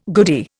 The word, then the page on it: goodie: Indian